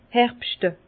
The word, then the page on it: herbschte: Bas-Rhin; Herrlisheim